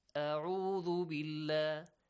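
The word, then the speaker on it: A'udhu billah (أعوذ): Some people say A'udhu. A'udhu. Instead of saying A'udhu, they say A'udhu. They change Ayn and Hamza. Or sometimes they pronounce both of them, Hamza, or both of them, Ayn. A'udhu billah. Or A'udhu billah. These ones are not correct. Which one is correct? A'udhu. A'udhu billah. Not A'udhu. A'udhu.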